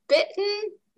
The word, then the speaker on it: bitten: Это flap T, это глотательный, вот как в слове bitten, да? – Bitten. – Мы не произносим там букву T, это мы все делаем с нашим, glottis, это называется glottal stop.